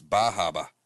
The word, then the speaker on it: Bar Harbor: Everyone always jokes that it's Bahaba, but yeah, you actually pronounce it a little more in depth.